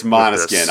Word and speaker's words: Måneskin: Well, is it Måneskin? Yeah. Is that the prep? Because you could go Måneskin. – It's Måneskin. I looked it up.